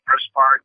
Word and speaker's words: Grosbard: Yeah, my handle is just my name, at Adam Grossbard. Adam, exactly how you spell it, and then Grossbard, G-R-O-S-B-A-R-D.